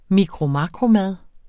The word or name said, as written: mikromakromad